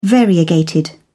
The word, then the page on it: variegated: British English